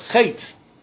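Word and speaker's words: Chet (חטא): The Baal Shem Tov points out that only the first two letters of the word of Chet is pronounced. The third letter of Aleph is silent, because that Aleph refers to the Ein Sof, the Aluf, who is the master of the world.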